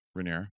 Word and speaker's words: Rhaenyra: Rhaenyra. – Rhaenyra, yeah. I said Rhaegara. No, that wasn't it. No hard G's. – Rhaegara is a different... – Rhaenyra, yeah, Targaryen.